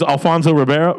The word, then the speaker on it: Alfonso Ribeiro: But let's not forget the other fun part of this was Alfonso Ribeiro. What's his name? Carlton from Fresh Prince. – Yep. Yeah, you had it. – Sure, yeah. – Alfonso Ribeiro. I just assumed I said his name wrong.